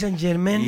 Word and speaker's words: Saint-Germain: Exacto. Saint-Germain. Pronuncie bien, querido.